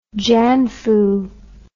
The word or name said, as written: janfu